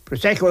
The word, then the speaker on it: Prosecco: Today is Prosecco Day. It is. I hope I pronounced that right. Prosecco Day. Prosecco, that's what it is, yeah.